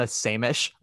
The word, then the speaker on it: Samish: Leona, I think, how do you pronounce her last name? Because I couldn't tell if it was so on the, because it's S-A-M-I-S-H. And I'm like, I think it's Samish. They keep saying Samish on that interview, which just sounds like sandwich, but.